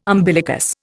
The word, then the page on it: umbilicus: Indian